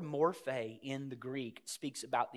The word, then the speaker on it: morphe: We would spell that M-O-R-P-H-E, morphe.